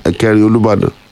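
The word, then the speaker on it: Ekerin Olubadan: It's Ekeri Olubadan. Say this properly. Ekeri Olubadan. – Okay, Ekeri Olubadan.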